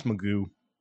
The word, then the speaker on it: McGough: It's spelled MCGOUGH. So it looks like Magoo or McGough, but it's pronounced Magoo.